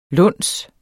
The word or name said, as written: luns